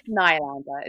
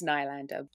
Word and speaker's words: Nylander: It's Nylander or Nylander? Am I saying it correctly? It's Nylander. It's Nylander.